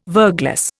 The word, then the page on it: verglas: Indian